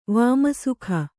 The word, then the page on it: ವಾಮಸುಖ: kannada